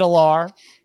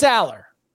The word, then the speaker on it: Allar: Because Franklin said Allar, but it's Aller.